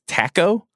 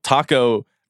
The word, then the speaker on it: Tacko: I don't know if you saw the video that Paolo Uggetti posted yesterday, but Tommy Heinsohn pronounced Tacko Fall's name as Tacko. – Nice. – Yeah. Tacko Fall? – Sure. – I don't know what that rhymes with. There's nothing that it rhymes with. Tacko rhymes with taco.